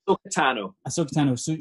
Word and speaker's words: Ahsoka Tano: Ahsoka Tano.